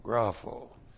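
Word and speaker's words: grapho (γράφω): G-R-A-P-H, long O, grapho.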